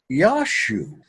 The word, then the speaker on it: Jesu: So we have now in the English a J-E-S-U, and that is pronounced Yashu.